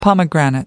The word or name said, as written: pomegranate